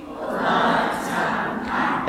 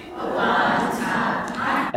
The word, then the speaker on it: Obatanpa: Again? – Obatanpa. – Again? – Obatanpa. – And that means good mother. Obatanpa means good mother.